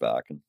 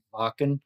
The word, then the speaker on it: Wacken: Wacken, pronounced Varken. – Varken.